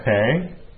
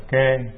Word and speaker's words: kê: Chữ này có khi người ta đọc là khể, có khi họ đọc là kê.